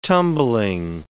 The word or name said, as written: tumbling